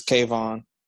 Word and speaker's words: Kayvon: I think it's Kayvon. – Yeah. – Kayvon Thibodeau, yeah.